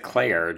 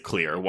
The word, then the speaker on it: Clear: But they pronounce it Claire, do they not? – No, they pronounce it Clear. Her name is Clear Rivers.